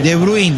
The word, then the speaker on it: De Bruyne: De Bruin, le digo yo. Porque los holandeses, los de Países Bajos, es como se pronuncia. No hay que inglesarlos.